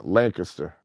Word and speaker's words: Lancaster: But, yeah, so it's like, and I noticed that once I moved to Lancaster, as they pronounce it. – Yeah. – Lancaster.